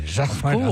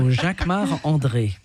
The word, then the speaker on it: Jacquemart-André: You say it like a Frenchman. – Jacquemart-André. Of course, because I'm French. So Jacquemart-André.